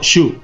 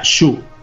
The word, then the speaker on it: Sioux: En inglés pronunciado Shu. Sioux en inglés se pronuncia Shu.